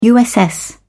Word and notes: uss: British English

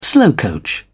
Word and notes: slowcoach: British English

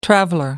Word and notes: traveller: American English

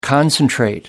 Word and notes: concentrate: American English

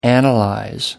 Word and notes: analyze: American English

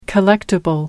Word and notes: collectible: British English

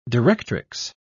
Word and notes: directrix: British English

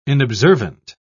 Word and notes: inobservant: British English

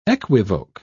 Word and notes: equivoque: British English

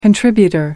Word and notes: contributor: American English

